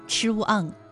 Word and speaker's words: 怆: 它的主要读音呢是四声，ch u ang chuang，表示伤感难过的样子。